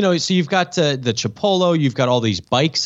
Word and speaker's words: Chipolo: I don't see them getting in on it, but, you know, Chipolo will be on it. Am I saying that right? – No. Here, this is how you say it. Chipolo.